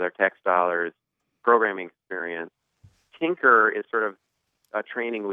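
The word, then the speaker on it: Tynker: Tinker is a terrific, it's inspired from Scratch from MIT.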